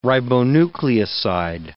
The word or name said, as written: ribonucleoside